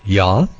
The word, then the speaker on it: Yah: Yod Hey, and it's pronounced Yah and spelled Y-A-H.